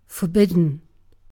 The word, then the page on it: forbidden: British English